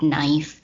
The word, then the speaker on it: knife: That title has some focuses on the word knife, which has something to do with sound and the way that we all agree to see a letter there, but we don't pronounce it.